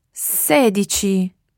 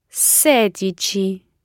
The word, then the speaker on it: sedici: Sedici. – Sedici. – Okay, so all of those numbers so far use that dici ending. And the stress goes on whichever syllable comes before the dici ending.